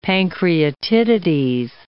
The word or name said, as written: pancreatitides